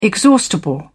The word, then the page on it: exhaustible: British English